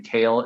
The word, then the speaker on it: Kehl: It's M-A-T-T-H-E-W, and Kale is spelled K-E-H-L.